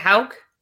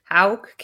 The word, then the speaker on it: Houck: Now, if the Rays win tonight, can Houck, is it Houck? – Houck. – Is that how you say it? Houck.